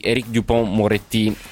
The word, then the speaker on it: Éric Dupond-Moretti: Il ministro della giustizia sarà infatti Éric Dupond-Moretti, lo pronuncio alla francese,